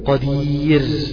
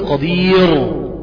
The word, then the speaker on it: قدير: فلما أنطقها أنا بهذه الكيفية قدير، تمام؟ بقى ده نطقي السليم له، ما أقولش قدير، لا، ياء ساكنة لابد أن ترقق الراء التي بعدها الموقوف عليها يعني.